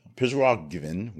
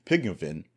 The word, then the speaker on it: Prigozhin: So somewhere early our time in our news coverage, or late-ish in the day for late Saturday there in Russia, Yevgeny Prigozhin, which is totally butchered, Prigozhin,